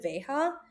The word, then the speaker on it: Veja: Is it Veja? – Yeah.